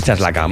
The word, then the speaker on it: Tlaxcala: Hasta el estado mexicano de Chaclaca. De Chacala, perdón, no. Tlaxcala. ¿Chaclaca? ¿Cómo pronuncias esto? T-L-A-X-C-A-L. Chaslaca. Bueno, pues Chaslaca. – Vamos a decir Chaslaca, ¿vale?